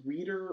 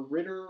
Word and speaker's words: Reiter: My last name is spelled R-E-I-T-E-R, because it's reader or ritter or something that no one else knows how to spell, but I don't know.